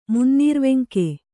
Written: ಮುನ್ನೀರ್ವೆಂಕೆ

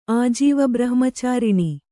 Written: ಆಜೀವಬ್ರಹ್ಮಚಾರಿಣಿ

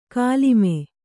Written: ಕುಲಿಮೆ

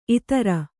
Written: ಇತ್ತೆರ

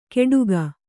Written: ಕೆಡುಕ

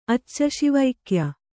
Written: ಅಚ್ಚಶಿವೈಕ್ಯ

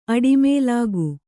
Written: ಅಡಿಮೇಲಾಗು

